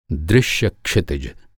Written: दृश्य

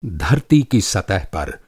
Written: सतह

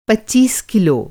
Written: पच्चीस

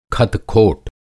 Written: खतखोट

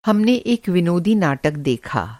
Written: विनोदी

